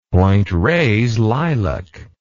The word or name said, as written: Point Reyes lilac